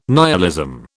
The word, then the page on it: nihilism: British English